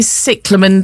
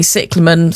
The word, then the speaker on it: cyclamen: It is definitely cyclamen. Terry from Batchmere is back again. – Lovely. – Who is watering their cyclamen. And, definitely cyclamen. Thank you.